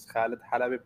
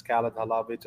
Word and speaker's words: Khaled Halabi: I'm from Lebanon, so the best way to pronounce my name is Khaled Halabi, but in the States, it's Khaled Halabi, just to make it easier for everyone.